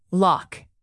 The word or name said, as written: loch